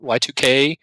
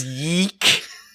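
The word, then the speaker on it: YIIK: That's how you say it. Yes. I was sincerely thought it was Yeek. – Yeek. – And it was like a sequel to Yogg. And I like, Yogg sounded cool. I'll play Yeek. – You know, the sad part is, I told him repeatedly it was Y2K, and he was like, no, it's not. You're saying it wrong, and I'm like, I'm pretty sure I'm not, but thanks, husband.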